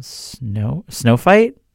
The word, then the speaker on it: SNOFHYT: Without further ado, this is their first song called Snow Fight, but it's spelled S-N-O-F-H-Y-T.